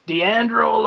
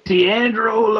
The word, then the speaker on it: Deandro: My name is Deandro Lopez. – I'm sorry? – Deandro Lopez. – Can you spell that for me, please? – D-E-A-N-D-R-O Lopez. You know, like Jennifer Lopez, the one with the cute buns.